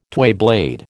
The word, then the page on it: twayblade: American English